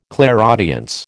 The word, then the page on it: clairaudience: American English